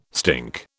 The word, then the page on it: stink: British English